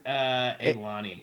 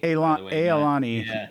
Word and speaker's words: ilani: It's pronounced Ilani. – Ilani. Yeah. – Yeah, Ilani. – What? – Ilani. There you go. Yes. Yes. – Yeah, it's Ilani, and it's Ilani.